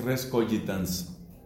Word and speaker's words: res cogitans: Y a ese tipo de cosas les da este apelativo, res cogitans, con G, que en la pronunciación usual del latín es res cogitans.